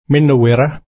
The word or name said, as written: minno-wirra